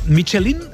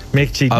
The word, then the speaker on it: Michelin: Oye, Michelin, ¿cómo se pronuncia definitivamente? – Michelin. A ver, aquí en la península ibérica hacemos Michelin. – Sí, claro. Claro, pero la realidad, tú eres un chef internacional. – Ya, pero... – ¿Tú cómo lo pronuncias? – Me cuesta ser tan tontorraco, ¿no? Dice Michelin. – ¿Michelin? Yo digo Michelin. – Vale. – Y cuando vas a una gala, pues escuchas Michelin a veces.